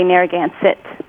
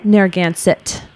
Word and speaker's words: Narragansett: So we actually around here, we say Narragansett with a little more emphasis on the T. – Narragansett. – Which is like the beer people know, Gansett's, that same pronunciation.